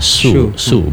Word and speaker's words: shu: Nabi Konghucu mengatakan, jika ada kata seperti itu, pasti itu maksudnya adalah kata atau huruf su. S-H-U. Ya, su bacanya.